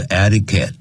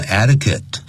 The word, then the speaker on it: etiquette: 嗯哼， etiquette 这个字我们教过，按照这个字典的发音是 etiquette。但实际很多外国人他念成 etiquette，像 the international，不是前面不需要加的 international etiquette，就是指的是国际礼仪啊。